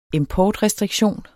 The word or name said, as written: importrestriktion